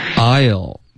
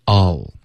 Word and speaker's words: I'll: I'll 这个发音也可以，可是我们最好用这个弱读 all, OK, 把它发成 all.